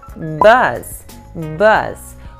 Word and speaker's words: bus: Então vamos começar falando da palavra bus. Bus. Quando você pronuncia bus, que é ônibus, é muito sutil, você abre bem pouquinho a boca, é como se você tivesse um sorrisinho, entendeu? Bus. Bus.